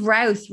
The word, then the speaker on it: Routh: I think it's Routh, rhymes with mouth.